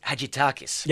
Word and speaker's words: Hadjidakis: from Manos Hadjidakis.